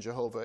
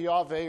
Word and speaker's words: Yahweh: That's not the proper pronunciation, Jehovah. It's likely Yahweh or something like that. We don't completely know.